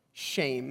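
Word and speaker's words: shame (שם): The second word for name is, it's spelled like Shem, but it's pronounced shame. Can you say shame? Shame sounds like just the English shame, but has no connection whatsoever, so don't think about that too much.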